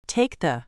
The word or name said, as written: take the